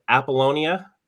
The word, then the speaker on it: Apollonia: And Paul, am I going to butcher your last name? Is it Apollonia? – Very good. No, you did not butcher it.